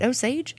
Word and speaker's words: Osage: Am I saying that right? Osage? – I think so. – Okay. – Yeah, like Osage County.